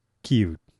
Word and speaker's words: キーウ: キーウ、